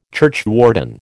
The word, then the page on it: churchwarden: American English